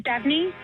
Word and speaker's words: Devney: My name's Devney. – I'm sorry, can you spell that? – D-E-V-N-E-Y.